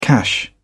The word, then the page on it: cash: British English